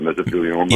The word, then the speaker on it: mesothelioma: Mesothelioma? – Yes.